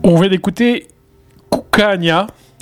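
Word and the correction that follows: Cocanha: C'est comme ça que ça se prononce. – Cucagno. – Cucagno. – Le A, Cucagno. Ouais, Cucagno.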